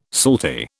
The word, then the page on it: salty: British English